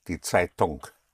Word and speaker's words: Zeitung: The NG in German is always pronounced, not like in English. That's why often you will hear Germans when they speak English. Saying I'm doing it. Yes, I'm singing. The NG is pronounced die Zeitung.